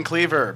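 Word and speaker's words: Kliewer: My name is Ben Cleaver, but it's spelled weird. At B-E-N-K-L-I-E-W-E-R. – It's not weird. It's German.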